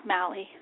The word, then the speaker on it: Mally: It's Mally.